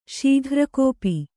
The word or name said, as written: ಶಕ್ರಗೋಪ